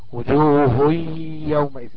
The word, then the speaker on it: وجوه يومئذ: وجوه يومئذ كأن في اتنين ياء، هو شكلهم كلهم واخدين تجويد قبل كده على فكرة على المنتدى، هو في على المنتدى تجويد.